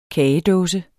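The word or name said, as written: kagedåse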